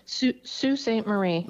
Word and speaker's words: Sault Ste. Marie: And you were born in, let me see if I pronounce this correctly, Sault Ste. Marie, is that right? – Sault Ste. Marie. – There you go.